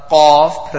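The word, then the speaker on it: Qaf (ق): That's the Qaf pronounced from the extreme back.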